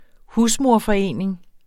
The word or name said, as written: husmoderforening